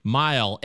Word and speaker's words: Mayall: Rick Mile, MAYALL.